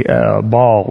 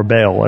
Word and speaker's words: Baal: Start worshiping other gods, especially Baal or Baal, as it's sometimes pronounced.